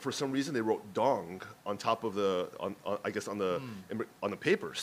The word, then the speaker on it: Dong: And then his last name is Dong, D-O-N-G, like ding dong.